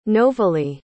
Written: novaleigh

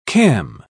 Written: cam

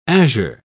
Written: azure

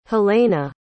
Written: helene

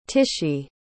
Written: Tishie

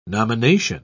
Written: nomination

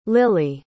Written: lili

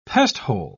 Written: pesthole

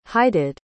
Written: heighted

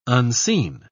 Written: unseen

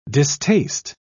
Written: distaste

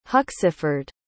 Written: Huxeford

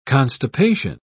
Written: constipation

